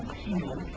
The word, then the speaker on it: Keettel: My name is William Kiesel, K-E-E-T-T-E-L.